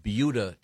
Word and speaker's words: Buda: No, that's how it's pronounced. – Okay. – Well, Jason, is it B-U-D-A? – Can you yes.